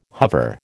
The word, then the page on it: hover: American English